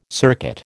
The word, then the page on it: circuit: American English